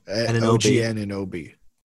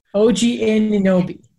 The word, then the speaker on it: OG Anunoby: OG Anunoby. – OG Anunoby.